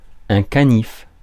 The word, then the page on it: canif: [ɛ̃ ka.nif]; /ka.nif/